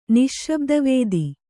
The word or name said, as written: ನಿಶ್ಶಬ್ದವೇದಿ